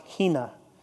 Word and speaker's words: hina: It's pronounced hina.